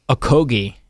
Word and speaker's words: Okogie: Josh Okogie, am I saying that right? – Yeah.